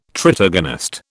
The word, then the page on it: tritagonist: British English